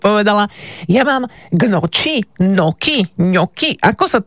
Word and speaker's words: gnocchi: No tak som sa do toho trošku zažrala a pozrela som sa, čo mi radí AI, tak vraj ňoki sa to má vysloviť, aj keď sa to píše gnocchi.